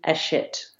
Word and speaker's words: Hachette: I've always called it Hatchet. – Oh, okay. Well, I'm not even pronouncing it correctly. It's supposed to be Hachette without the H.